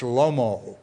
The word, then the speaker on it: Shlomo: That is the Hebrew pronunciation of the word that you see in front of you. Solomon, we say in English, Shlomo in Hebrew.